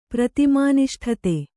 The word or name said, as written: ಪ್ರತಿಮಾನಿಷ್ಠತೆ